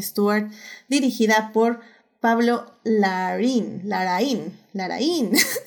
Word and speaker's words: Larraín: Laraín. Es que es con acento en ahí aparte, entonces Laraín.